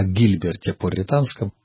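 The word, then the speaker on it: Гильберт Порретанский: Или, как часто его именуют на современный французский манер, Жильбер де ля Порре. Иногда это имя произносит так, соединяя французский и латинский язык, Жильбер Пуританский. Но я думаю, что правильнее, наверное, об этом философе говорить как о Гильберте Пуританском.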